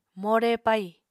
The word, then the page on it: more pai: Apatani